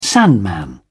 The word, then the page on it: sandman: British English